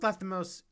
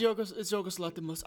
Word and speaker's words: Lanthimos: So Yorgos Lanthimos. Am I pronouncing his name right? Yeah. – It's Yorgos Lanthimos. I think. – Lanthimos. I was forgetting the end. Yorgos Lanthimos. – Lanthimos, okay. Yes.